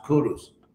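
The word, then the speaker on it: Kuruš: This Cyrus the Great was actually a Vedic king who started the Persian Empire, ironically, because his name in his own language was actually pronounced Kurus.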